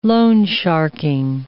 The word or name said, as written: loan-sharking